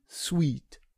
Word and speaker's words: Suite: Die G Suite. Genau. Oder Suite. Ja, Suite, sag ich mal. Genau. Ja. Ist das jetzt französisch ausgesprochen?